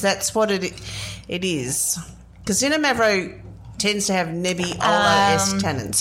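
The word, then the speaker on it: Xinomavro: It's spelled X-I-N-O. Mavro, so Zinno Mavro.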